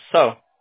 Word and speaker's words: Seo: Oh, believe it or not, it's spelled S-E-O. It's pronounced so.